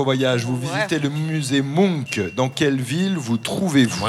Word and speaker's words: Munch: Ça s'écrit M-U-N-C-H et je crois qu'on prononce Munch.